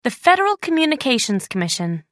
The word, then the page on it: federal communications commission: American English